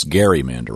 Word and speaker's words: gerrymandering: It's gerrymandering. – Gerrymandering. It's everyone gets it wrong. – It's a hard G.